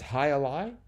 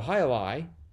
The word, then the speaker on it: jai alai: It's pronounced I lie. – Is it? – I believe. – Oh my gosh. See, most of these words, you know, I've never heard anyone say. They just exist in crosswords for my. So it's Hai Alai? – I think so. – Oh, okay, okay. – That's how I've always heard it.